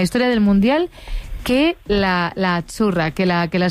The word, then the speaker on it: Azzurra: Azzurra. – Azzurra. Mira, ¿ves? – Azzurra. Es que... Azzurra. Sí, como porque pizza, la Z no es la Z como es decimos en catalán tan sonora, no.